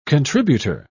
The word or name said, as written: contributor